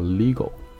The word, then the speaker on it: Lego: 然后他创造的这个品牌叫 Lego，所以说大家看到的中文的翻译其实不叫，就是说你你按音中文发音不叫乐高，叫 Lego。